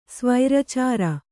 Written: ಸ್ವೈರಚರ

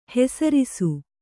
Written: ಹೆಸರಿಸು

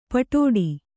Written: ಪಿಡ್ಡ

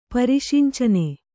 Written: ಪರಿಷಂಚನೆ

